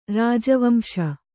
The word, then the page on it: ರಾಜವಂಶ: kannada